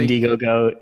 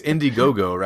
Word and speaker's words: Indiegogo: I think it's Indiegogo, right? I don't know. – Yeah. Yeah, indie, like independent.